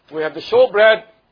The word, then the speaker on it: shewbread: And if you don't know what a showbread is, now, you know, King James is written as S-H-E-W-B-R-E-A-D, which is showbread. A showbread. Like, you pronounce it as showbread. Like show.